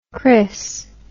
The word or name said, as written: chrys